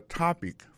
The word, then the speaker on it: topic: What's your topic for today? Alors, mot topic là, qui signifie sujet, ça veut dire accent tonique là tombé sous premier syllabe là.